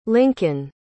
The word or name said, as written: lincoln